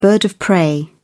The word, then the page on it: bird of prey: British English